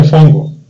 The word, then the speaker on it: fungo: Se escribe fungo, pero se pronuncia fongo.